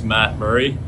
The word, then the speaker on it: Matt Murray: Hi, my name's Matt Murray, pronounced Matt Murray.